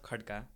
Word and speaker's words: Khadka: So my name is Sulov Khadka.